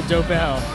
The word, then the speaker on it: Dobel: It was some doble. I'm sorry, dobel. – Dobel.